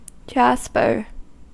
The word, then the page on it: jasper: American English